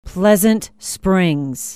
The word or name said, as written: pleasant springs